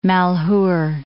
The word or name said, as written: Malheur Lake